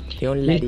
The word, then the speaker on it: Larregui: ¿Larregui? Sí, lo dije bien. Sí. – León Larregui.